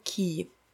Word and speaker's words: quay: Слово «набережная» или «причал», которое пишется Q-U-A-Y, и хотелось бы его прочитать, наверное, как-нибудь типа quay, но произносится оно «key».